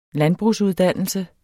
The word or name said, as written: landbrugsuddannelse